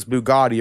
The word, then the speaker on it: Bugatti: They're like, I want this fucking car because it's Bugatti. It rhymes with body.